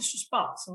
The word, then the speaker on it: suspense: Hein, oui, mais c'est un terme qui est francisé. – D'accord, très bien. – Mais euh, on va le prononcer suspense.